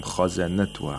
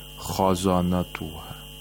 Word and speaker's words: خَزَنَتُهَا: وَقَالَ لَهُمْ خَزَنَتُهَا. خَزَنَتُهَا. Again, خَا is a letter of إِسْتِعْلَى. خَزَنَتُهَا. And all the other letters in this word are letters of استفال, so you have to pronounce them with ترقيق.